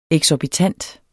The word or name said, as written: eksorbitant